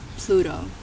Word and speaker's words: Plvto: However, in terms of this persona that you have brought to light, I am Plvto.